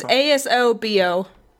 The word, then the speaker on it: Asobo: Asobo. I'm hoping I'm saying that right. Asobo? Asobo? Studio? – Let's look it up. – It's Asobo. But they've done a wonderful job.